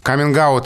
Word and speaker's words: каминг: каминг есть, наверное, смысл произносить со звонким «г», потому что перед гласным сильная позиция и нет оснований для оглушения.